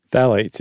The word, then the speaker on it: Phthalates: Phthalates? – Phthalates, yeah.